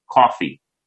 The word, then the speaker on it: Coffey: And one of them is a guy named Lieutenant Gerald Coffey, as in coffee, spelled the same.